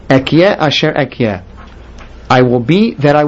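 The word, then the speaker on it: Ehyeh asher ehyeh: Now here, the next word, we pronounce it Ekye Asher Ekye because it's being used to mean Hashem's name.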